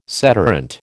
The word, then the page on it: sederunt: American English